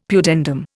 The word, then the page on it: pudendum: Indian